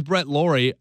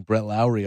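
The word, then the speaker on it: Lawrie: Put Jed Lowry at second base, and apparently, at least people pronounce his name right. I mean, now we don't have to hear people saying Brett Lowry all the time.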